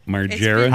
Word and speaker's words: Margera: Margera.